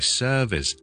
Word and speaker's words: service: 今日同大家解答有个听众嘅问题，佢就问招叔叔两个字嘅发音，第一个系服务，第二个系一个表面服务，我哋 service S E R V I C E。大家听到个 V 咧，都知道咧，我哋要做 V 呢个音啦，即系有 voicing 啦。